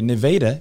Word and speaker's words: Nevada: Nevada, if you come down southeast, that is how you say it, folks.